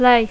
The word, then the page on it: lay: English